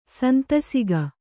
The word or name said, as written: ಸಂತಸಿಕೆ